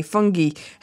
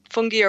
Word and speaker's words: fungi: Fungi have a function in relation to that. – Yeah, I think you can pronounce it either way, fungi or fungi.